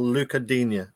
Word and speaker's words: Digne: Abdoulaye Doucouré, Ben Godfrey, or Luca Digne? And beautifully pronounced, if I say so myself. – Yeah, that's perfect.